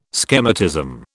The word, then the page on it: schematism: British English